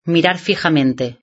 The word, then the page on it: mirar fijamente: Spain Spanish